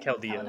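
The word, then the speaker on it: Keldeo: Yeah, they all have Calado, is that how you say it? – Caldeo. – Caldeo.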